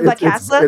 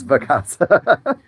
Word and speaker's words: Vacasa: Was it Vacasa? – It's Vacasa.